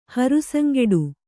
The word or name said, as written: ಹರುಸಂಗೆಡು